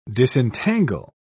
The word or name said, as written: disentangle